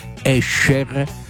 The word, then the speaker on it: Escher: Poi festeggiamo un artista che ha fatto impazzire il nostro cervello, non so se avete presente, si chiama Escher. È questa la pronuncia esatta, attenzione, Escher, perché si pronuncia come la SC come pesce, alcuni dicono Esche, altri Escher, Escher.